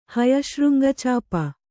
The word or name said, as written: ಹಯಶೃಂಗಚಾಪ